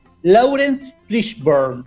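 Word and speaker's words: Laurence Fishburne: Lawrence Fishburne.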